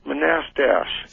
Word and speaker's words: Manastash: Do you know where Manistash? – Yeah, it's pronounced Manastash. – Figures. Manastash, all right.